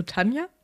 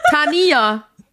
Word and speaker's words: Tania: Meinst du Tanja? – Tania. Mit I. – Ja, aber Tanja, es gibt auch Tanjas, die mit I geschrieben werden. Ja, aber es wird Tania ausgesprochen, sag ich. Weil sie ist eine Elfe.